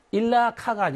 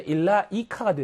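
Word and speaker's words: 일라 이카 (إليك): 그래서 일라 카가 아니라 일라 이카입니다.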